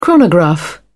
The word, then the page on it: chronograph: British English